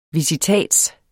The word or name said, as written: visitats